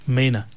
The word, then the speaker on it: mainour: We've given the correct answer, and the word is, it's pronounced as Mena. But it's spelled very much differently. It's spelled M-A-I-N-O-U-R.